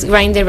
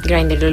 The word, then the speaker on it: Grindr: es Grindr, ¿verdad? Así lo pronunciaste tú. – Sí. – Grindr, lo he estado diciendo mal.